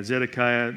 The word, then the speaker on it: Zedekiah: Yeah, Zedekiah.